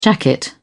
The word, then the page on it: jacket: British English